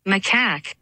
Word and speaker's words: macaque: And it's not dirty unless the person saying it is from Boston. – Oh, McCaw. – See if I can get it to work. – Is that right? – Attack. Macaque. – Yeah, there.